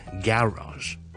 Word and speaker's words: garage: 咁咧英国人咧就会读成咧 garage，跟住 rage 后面有个 z 咁嘅音嘅，OK，garage。好啦，美式英文又点读咧？美式咧通常咧，我哋系读咧系 garage，个重音咧就会放咗喺后面嘅，咁所以就系 garage，咁就亦都系读 z 咁嘅音啦。